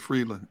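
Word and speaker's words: Frelund: So Cynthia Freudlin, I believe is how you pronounce it, from the NFL. Friedland? – Is it Freeland? I believe it's Freeland.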